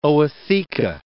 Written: ootheca

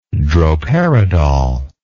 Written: droperidol

